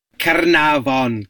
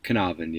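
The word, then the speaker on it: Caernarfon: Carnarvon Castle is, he put some effort into the Welsh pronunciation, but Carnarvon, yeah.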